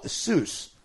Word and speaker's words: Seuss: North Dakota environmental scientist Bill Seuss, like Dr. Seuss, spelled the same way,